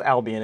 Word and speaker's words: Albion: And speaking of them, a 2-1 win against Brighton and Hove Avion. I'm pretty sure I butchered the second part of that. It doesn't matter. And Hove Albion, excuse me.